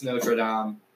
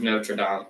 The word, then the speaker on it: Notre Dame: And you said it wrong. It's Notre Dame. Hunchback of Notre Dame.